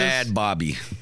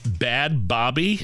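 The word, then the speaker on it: Bhad Bhabie: Bad Bobby. – Bad Bobby? – Yeah.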